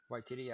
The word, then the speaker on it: Waititi: Waititi, I think. – Waititi, yeah.